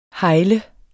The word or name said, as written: heile